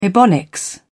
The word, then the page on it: ebonics: British English